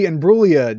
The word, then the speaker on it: Imbruglia: The winner for the Natalie Imbruglia Don't Pronounce the Fucking G Torn Award is Skinamarink.